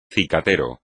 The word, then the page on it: cicatero: espagnol